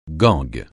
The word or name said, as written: gangue